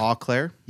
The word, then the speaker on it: Eau Claire: Guess Eau Claire. – Yeah, you're probably right.